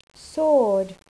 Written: sword

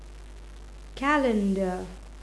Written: calender

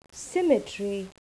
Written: symmetry

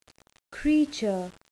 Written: creature

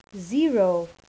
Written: zero

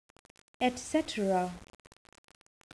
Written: etcetera

